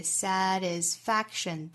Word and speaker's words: satisfaction: Career satisfaction 同样呢重读是在倒数第二个音节 satisfaction da da da da satisfaction da da da da doesn't come from 若读成 from what you do what you do, 这里面同样把 t 的音直接略掉就可以了。